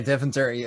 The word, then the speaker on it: Deventer: I am familiar with the area of Deventer. Is that correct, the pronunciation? Okay. – Yeah, Deventer. Deventer.